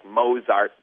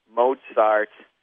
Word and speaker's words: Mozart: You said, like, Mozart Bach. But there's a T in there that is not written but should certainly be pronounced. Mozart, you can't disrespect the old guys like that.